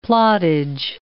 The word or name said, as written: plottage